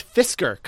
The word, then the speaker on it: Fisker: It's Fisker Karma. Fisker. F-I-S-K-E-R. Fisker. – Oh, okay. Yeah. You're right. Fisker Karma. – What would be fun about the Fisker Karma,